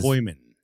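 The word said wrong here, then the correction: Caoimhín: It's pronounced Kuivin. – Kuivin. – I'm not making a joke. That is how it's. – Hold on, everybody, be quiet. One more time, say it. – Queeving.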